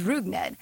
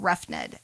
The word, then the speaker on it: Rugned: When we first talked about Odor a long time ago, I had to look up how to pronounce his first name, and it was Rugned. And then I talked about him, I think, last week or the week before, and I said Roughned, and that's just wrong.